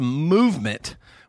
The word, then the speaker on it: MVMT: MVMT Watches, which is pronounced movement, was founded on the belief that style shouldn't break the bank.